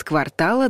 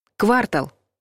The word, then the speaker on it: квартал: Во всех значениях слово правильно произносить с ударением на последнем слоге. Квартал.